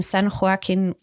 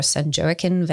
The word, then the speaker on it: San Joaquin: You have grown up in San Joaquin Valley or San Joaquin Valley, depending on how Latino one wants to be in the pronunciation.